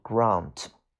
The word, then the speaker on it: grant: 那么它的英式发音读 grant，就是恩赐，比如上天恩赐了。